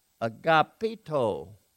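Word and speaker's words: agapito (ἀγαπητός): This is taken from a word pronounced agapito, which means loved or beloved.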